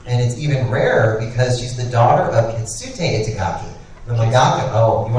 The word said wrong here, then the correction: Keisuke: Oh, no, I'm just saying it's pronounced Keisuke.